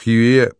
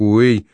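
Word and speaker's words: Huế: как снайпер достал его великолепным выстрелом в «Хьюе», произносится как «Уэй», завернутый в подарочную обертку номер порножурнала «Черный поясок».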